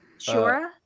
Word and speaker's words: Sciorra: Sciorra.